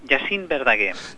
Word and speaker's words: Jacint Verdaguer: Estaba dudando al pronunciar el nombre del centro. Es Centre Educatiu. – Sí. – Jacint Verdaguer. – Jacint Verdaguer. – Lo habéis dicho muy bien.